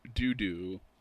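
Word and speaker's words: Deux-Deux: The other recurring character is Sergeant Dudu, as in Tutu, pronounced Dudu, as in number two,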